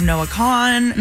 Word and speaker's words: Noah Kahan: And I'm just proud of Noah Kahan.